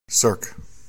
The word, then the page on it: CIIRC: American English